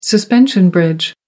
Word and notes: suspension bridge: American English

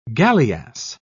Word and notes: galleass: British English

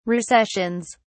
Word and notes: recessions: British English

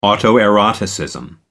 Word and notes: autoeroticism: American English

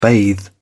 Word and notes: bathe: British English

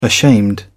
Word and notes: ashamed: British English

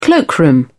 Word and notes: cloakroom: British English

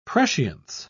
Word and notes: prescience: British English

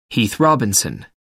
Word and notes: heath robinson: American English

